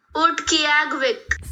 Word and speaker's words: Utqiagvik: Utqiagvik.